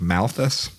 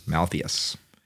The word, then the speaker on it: Malthus: He was an economist in the 19th century, Thomas Malthus, I think is Malthus, Malthus.